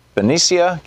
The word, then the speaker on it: Benicia: Denise, in Benicia, California.